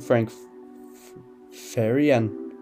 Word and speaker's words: Farian: Men vi starter i 1970'erne, hvor at produceren Frank Farian, ja, det er sådan, man udtaler, han var sanger og sangskriver, og han optog en sang, der hedder Baby Do You Wanna Bump i december 1974.